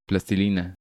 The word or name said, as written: Plastilina